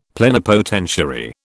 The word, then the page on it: plenipotentiary: British English